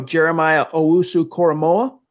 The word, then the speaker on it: Jeremiah Owusu-Koramoah: I'm going to butcher his name, so I'm going to say J-O-K, but here I go. Jeremiah Owusu-Koramoah.